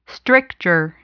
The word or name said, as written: stricture